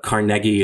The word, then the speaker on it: Carnegie: But Carnegie is correct. And I think in the original Scottish, Carnegie was what he grew up pronouncing.